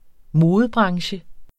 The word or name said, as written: modebranche